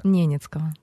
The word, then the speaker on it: Ненецкого: Ненецкого.